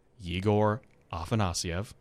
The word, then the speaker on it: Yegor Afanasyev: By the way, that's how you say his name, Yegor Afanasyev. Just wanted to clear the air on that.